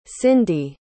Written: cyndi

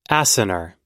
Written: acinar

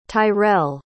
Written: tyrell

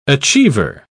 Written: achiever

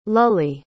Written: Lulie

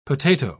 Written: potato

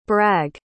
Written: bragg

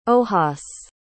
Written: ojas

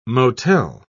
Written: motel

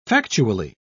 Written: factually

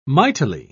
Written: mightily

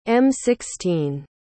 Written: m16

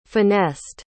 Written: finessed

